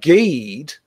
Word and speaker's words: Ghede: But Geed, like it's spelled G-H-E-D-E, but Geed is a voodoo, that's all sort of voodoo stuff.